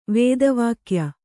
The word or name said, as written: ವೇದವಾಕ್ಯ